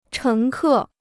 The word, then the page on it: 乘客: chéng kè